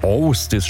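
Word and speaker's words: Ásdís: Austis spricht man sie original, aus Island kommt sie, lebt in Berlin.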